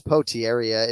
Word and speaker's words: Potieria: Yeah, so it's Poteria. It's spelled P-O-T-I-E-R-I-A.